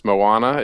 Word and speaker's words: Moana: I believe it's pronounced Moana.